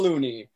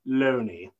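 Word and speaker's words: Looney: And here's my great uncle Loony. – No. It's pronounced Loney.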